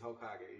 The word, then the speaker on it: Hokage: You did, it's Hokage, yeah. – What is Hokage for my audience? – Hokage, it derives from an anime, which is a Japanese cartoon, if you don't know what that is.